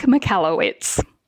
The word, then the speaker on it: Michalowicz: How do you say Mike's last name? – Mike Michalowicz.